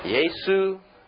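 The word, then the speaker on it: Yesu (耶稣): J-E-S-U-S. And Jesus, Jesus. Or, in Mandarin, is Yesu.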